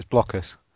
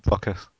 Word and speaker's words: Blokus: It's Blokus. It's definitely Blokus. – Oh, yeah. That's what I said. Yeah, that's right. Yeah. – It's block. Why? Because it's got blocks.